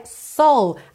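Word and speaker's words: Seoul: Ah, uma dica que Seul em inglês se pronuncia soul, assim como a alma ou a soul music, S-O-U-L, soul, ok?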